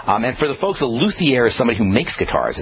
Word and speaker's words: luthier: Are you a luthier? Is that how you say it? Luthier? – A luthier.